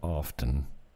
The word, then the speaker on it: often: I just found out that often... is a silent T, and it's often. How many people say often?